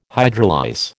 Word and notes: hydrolyse: American English

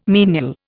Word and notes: menial: Indian

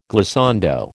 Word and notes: glissando: American English

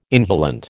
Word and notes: inhalant: American English

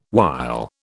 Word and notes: while: British English

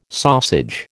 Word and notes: sausage: American English

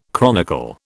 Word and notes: chronicle: British English

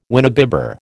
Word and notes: winebibber: American English

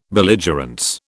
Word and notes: belligerence: British English

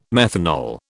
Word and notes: methanol: British English

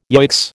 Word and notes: yoicks: American English

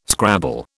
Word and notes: scrabble: British English

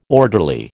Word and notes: orderly: American English

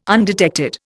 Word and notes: undetected: Indian

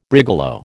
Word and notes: brigalow: American English

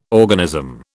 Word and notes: organism: British English

